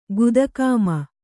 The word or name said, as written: ಗುದಕಾಮ